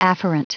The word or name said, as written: afferent